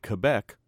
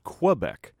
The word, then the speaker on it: Quebec: You may have noticed that I pronounce the word Quebec, Quebec, unlike in some places of the United States where the word is pronounced Quebec.